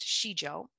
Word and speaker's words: Sijo: I learned about Shijo, and it's written S-I-J-O, pronounced Shijo, a little over a decade ago.